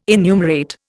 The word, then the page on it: enumerate: Indian